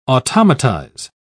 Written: automatize

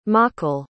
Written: mochel

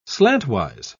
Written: slantwise

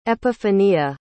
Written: epifania

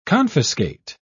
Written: confiscate

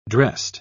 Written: dressed

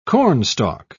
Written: cornstalk

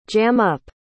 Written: jam-up

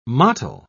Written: mottle

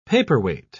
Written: paperweight

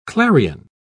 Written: clarion

